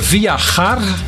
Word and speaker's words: viajar: Ik heb hier Viajar. Viajar. Maar gar in het Spaans is jar. Hè? De J spreek je uit als een G.